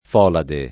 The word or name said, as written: folade